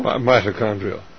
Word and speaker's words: mitochondrial: What he did was, and you could correct me on the pronunciation, I believe it's mitochondrial? – Mitochondrial.